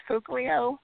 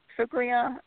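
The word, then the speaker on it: Fauglia: it's pronounced Fuglia, Fuglia, in Pisa area. – It's F-A-U-G-L-I-A is the beautiful little town on the hill that is, you know, our home.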